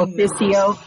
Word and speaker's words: ex officio: Non-voting officio.